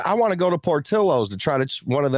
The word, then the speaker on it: Portillo's: No, it's Portillo's.